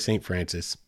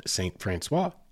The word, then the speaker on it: St. Francois: People up there will say St. Francis. It's a colloquial thing. It's spelled St. Francois. It's French. That's the proper pronunciation.